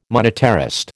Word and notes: monetarist: American English

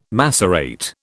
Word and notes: macerate: British English

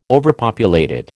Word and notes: overpopulated: American English

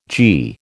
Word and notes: g: American English

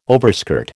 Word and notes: overskirt: American English

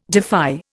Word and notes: defy: Indian

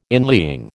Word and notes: inlying: American English

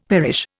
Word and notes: perish: Indian

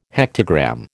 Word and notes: hectogram: American English

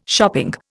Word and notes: shopping: Indian

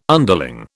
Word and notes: underling: British English

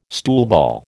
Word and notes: stoolball: American English

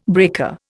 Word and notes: breaker: Indian

